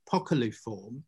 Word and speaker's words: poculiform: And this is called peculiform, or poculiform. I should say it properly, poculiform.